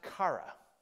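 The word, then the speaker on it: chara (χαρά): The Greek word for that is a word that we would pronounce chara. Okay, in our English letter, that's not the way it's spelled in Greek, but in our English letters, it would be C-H-A-R-A, chara, okay?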